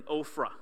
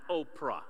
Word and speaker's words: Ophrah: Verse 27, "Gideon made a sacred ephod from the gold that he put in Ophrah," which it was the town he was from. Not Oprah, okay? Ophrah. Ophrah. I was reading it, Oprah. So I got to look at pronunciation.